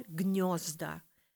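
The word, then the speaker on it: гнёзда: Mehrzahl Gniozda. Weich ist in der Aussprache äh, äh, äh fast O, ja.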